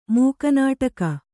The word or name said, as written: ಮೂಕನಾಟಕ